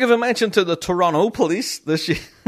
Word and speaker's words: Toronto: Now, whenever we're here in Northern Ireland, I would pronounce the Toronto Three syllables, right? Toronto police. Uh, but I've been told that's wrong. Even by guys in the band said, no, it's not Toronto, it's Toronto. You don't pronounce the last T.